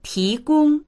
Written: ti2gong1

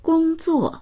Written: gong1zuo4

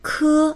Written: ke1

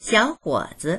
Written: xiao3huo3zi

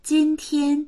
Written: jin1tian1

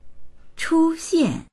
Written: chu1xian4

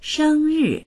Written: sheng1ri4

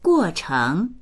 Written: guo4cheng2

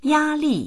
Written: ya1li4

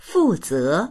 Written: fu4ze2

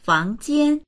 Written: fang2jian1

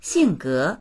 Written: xing4ge2

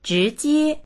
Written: zhi2jie1

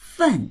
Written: fen4

